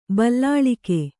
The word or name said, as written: ಬಲ್ಲಾಳಿಕೆ